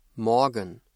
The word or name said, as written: Morgen